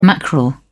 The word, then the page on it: mackerel: British English